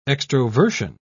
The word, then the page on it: extroversion: British English